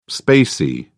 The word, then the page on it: spacey: British English